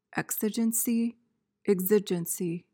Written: exigency

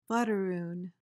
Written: blatteroon

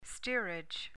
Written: steerage

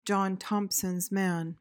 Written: john thomsons man

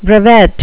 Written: brevet

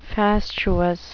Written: fastuous

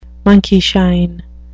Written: monkeyshine

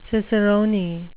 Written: cicerone

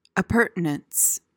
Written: appurtenance

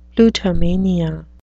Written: plutomania

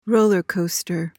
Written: rollercoaster

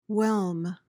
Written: whelm

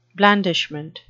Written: blandishment

